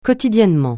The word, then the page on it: quotidiennement: francais